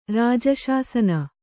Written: ರಾಜಶಾಸನ

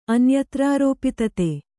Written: ಅನ್ಯತ್ರಾರೋಪಿತತೆ

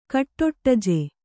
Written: ಕಟ್ಟೊಟ್ಟಜೆ

